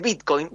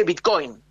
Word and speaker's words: Bitcoin: Bueno, Adorni sale y dice, en vez de decir Bitcoin, dice Bitcoin. O sea, nadie de toda la comunidad cripto le dice Bitcoin con acento en la O al Bitcoin.